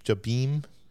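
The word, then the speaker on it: Jabiim: And he learns he's on a planet called Jabim. – Jabim.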